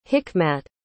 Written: hikmat

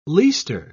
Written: leister

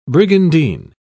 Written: brigandine

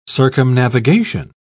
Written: circumnavigation

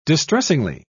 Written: distressingly

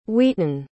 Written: wheaton

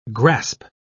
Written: grasp

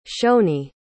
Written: shoney

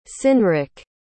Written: cynric

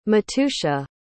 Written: matusha

